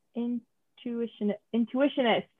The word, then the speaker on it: intuitionist: Oh, wait, am I pronouncing that wrong? Intuitionist. – Oh my God, you're right. I thought it was institutionist as well. Wow. – As soon as I read that line, I'm like, we're going to have people yelling at us when we start talking about this. – But then they'll hear me. – And I have to be like, can I pronounce it correctly? Yeah. So it's intuitionist.